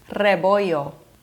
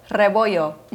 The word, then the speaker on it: Rebollo: Rebollo. – I got it completely wrong. Can you say that one more time? – Yes. You got to roll the R, you know? It's Rebollo.